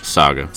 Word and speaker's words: Saga: Is it Saga or Sage? – Saga.